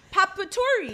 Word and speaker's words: potpourri: It's pronounced papaturi. – P-O-T-P-O-U-R-R-I. – Wait, say it again. – P-O-T-P-O-U-R-R-I.